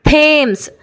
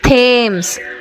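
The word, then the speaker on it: Thomas: ಇನ್ನು ಕೆಲವು ಸಲ ಟಿ ಎಚ್ ಕನ್ನಡದ ಥದಾಗೆ ಉಚ್ಚಾರ ಆಗುತ್ತೆ. ಥೇಮ್ಸ್, ಟಿ ಎಚ್ ಎ ಎಮ್ ಇ ಎಸ್, ಟಿ ಎಚ್ ಎ ಎಮ್ ಇ ಎಸ್, ಥೇಮ್ಸ್. ಟಿ ಎಚ್ ಒ ಎಮ್ ಎ ಎಸ್, ಥಾಮಸ್, T H O M A S, ಥಾಮಸ್. ನೋಡಿ, ಕನ್ನಡದಲ್ಲಿ ಥ ಉಚ್ಚಾರ ಮಾಡ್ತೀವಲ್ಲ, ಅದೇ ರೀತಿ ಟಿ ಎಚ್ನ ಉಚ್ಚಾರ ಬಂತು.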